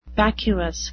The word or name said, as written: vacuous